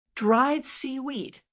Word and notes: dried seaweed: American English

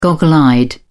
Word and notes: goggle-eyed: British English

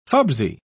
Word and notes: fubsy: British English

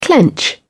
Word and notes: clench: British English

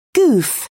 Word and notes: goof: British English

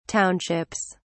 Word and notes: townships: British English

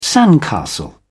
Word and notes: sandcastle: British English

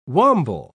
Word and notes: wamble: British English

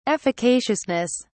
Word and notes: efficaciousness: British English

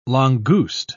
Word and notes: langouste: British English

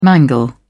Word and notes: mangle: British English

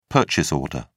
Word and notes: purchase order: British English

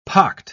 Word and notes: pocked: British English

